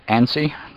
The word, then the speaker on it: ANSI: Okay, the next one I've got here is the ANSI, at least that's how Jaws pronounces it, A-N-S-I.